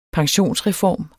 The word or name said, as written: pensionsreform